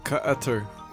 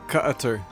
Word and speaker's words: Qatar: Qatar. – What? Wait, say that again? – Qatar. – You somehow made it three syllables. How did you do that? – It's Qatar.